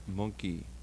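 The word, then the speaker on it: Mynci: Gorky Zygotic Monkey. And it's much disputed how that last word is pronounced, because it's a Welsh spelling, and this is a Welsh band, but it's Monkey.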